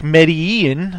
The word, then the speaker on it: Medellin: Now Entourage this week, Vince is trying to make Medellin. Am I saying the name right? – Mm-hmm. Yeah, because I noticed like they, you know, Eric will say it one way and then Ari will say it another way.